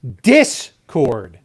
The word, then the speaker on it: Discord: It's Discord. – Discord. – The stress is on the first syllable.